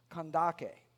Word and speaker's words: Kandake: He was an important guy because he was the treasurer for, you see it says Candace, that's kind of a Latinized version of a term for a queen historically in Ethiopia. And that was, it's actually pronounced Kandake, Kandake, all right?